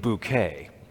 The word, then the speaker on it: Bucket: It is hilarious because it all focuses on this one main character named Hyacinth Bucket, but she pronounces her name Bouquet. And the reason that she pronounces it Bouquet is because she is a social climber.